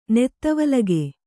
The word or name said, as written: ನೆತ್ತವಲಗೆ